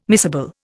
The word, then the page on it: miscible: Indian